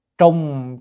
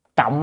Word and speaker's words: trọng: Trùng chú thử kinh, chữ này có hai âm, đọc là trùng hay trọng, nhưng mà ở đây á đọc cái trọng á, là tính từ ha, tính từ thì không đúng.